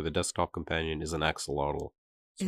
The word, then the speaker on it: axolotl: I think it's an axolotl. Is that not how you say it? – Oh, yeah, axolotl.